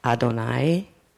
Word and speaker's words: Adonai: But I told him the one word was, how he pronounced it, Adonai. And I said, I believe that's Adonai, which which is one name for God.